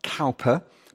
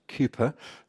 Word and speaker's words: Cowper: So spelt Cowper, but apparently pronounced Cowper, the Anglican hymn writer from the end of the 18th century.